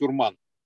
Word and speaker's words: Урман: То есть, например, Урман, ну, правильно, говорит, э, нас э, башкиры, татары поправляют, что правильно его надо называть Урман. У них ударение на букву А.